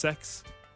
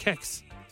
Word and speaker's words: CEX: CEX, I used to call it. – Oh, yeah, yeah. – You know, that's not... – CEX. – That's pronounced sex.